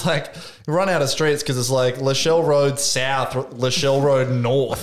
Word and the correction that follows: Lascelles: Okay, that's pronounced Lascelles.